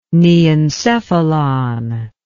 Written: neencephalon

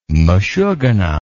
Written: meshugana

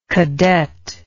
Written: cadetcy

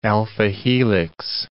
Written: alpha-helix

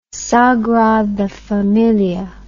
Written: sagrada familia

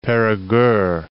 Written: Perigueux

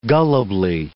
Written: gullibly

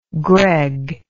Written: Greg

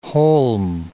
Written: holm

